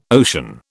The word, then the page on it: ocean: British English